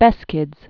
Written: Beskids

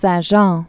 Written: Saint Jean

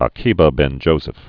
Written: Akiba ben Joseph

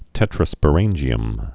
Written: tetrasporangium

